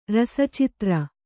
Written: ರಸಚಿತ್ರ

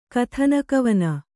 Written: ಕಥನಕವನ

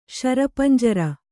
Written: ಶರಪಂಜರ

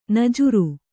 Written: ನಾಜರು

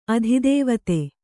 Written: ಅಧಿದೇವತೆ

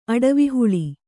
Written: ಅಡವಿಹುಳಿ

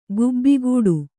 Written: ಗೇಬೆಕಟ್ಟು

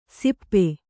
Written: ಸಿಪ್ಪೆ

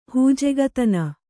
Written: ಹೂಜೆಗತನ

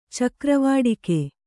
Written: ಚಕ್ರವಾಡಿಕೆ

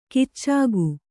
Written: ಕೀಚಕ

